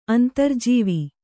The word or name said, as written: ಅಂತರ್ಜೀವಿ